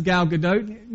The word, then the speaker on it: Gal Gadot: I had to look up how you pronounce her name. That's Gal Gadot.